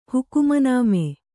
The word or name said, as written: ಹುಕುಮನಾಮೆ